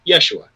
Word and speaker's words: Yeshua: In Hebrew, it would have been pronounced Yeshua.